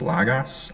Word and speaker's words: logos: It's pronounced logos, as you can tell.